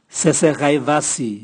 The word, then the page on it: sese gaivasi: Raga